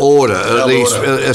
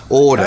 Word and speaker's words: Oerder: Order. Spelling is O-E-R-D-E-R. – Look at Order. – Order.